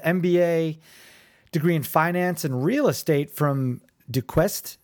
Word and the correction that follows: Duquesne: How do you say that? – Duquesne University. Duquesne. – Oh, Duquesne. Yep. Duquesne. Yeah, that's French, right? – Yes.